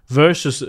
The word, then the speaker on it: versus: Vielleicht versus ist es mit W. Das, ah, es ist mit versus. W. Versus wird's ausgesprochen. Und wir sagen mal versus. Das klingt in meinen Ohren völlig gleich, aber nee. ich bin auch Deutscher. Du, du, musst am Anfang diesen, diesen Knubbel da machen.